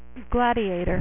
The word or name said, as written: Gladiator